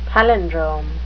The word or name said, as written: palindrome